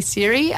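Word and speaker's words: CIRE: There's a few different pronunciations of it going around, but it's definitely Siri, an acronym.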